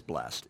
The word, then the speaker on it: blessed: I want to back up into verse 1 a little bit and where it says blessed, or if somebody wants to get quasi-King James, they say blessed, but it's blessed is how it's pronounced.